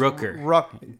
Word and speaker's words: Rooker: How do you pronounce his name? – It's Rooker. Two O's. Like, Rooker.